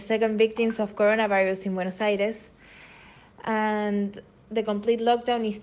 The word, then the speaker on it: Buenos Aires: First of all, that is how you say Buenos Aires.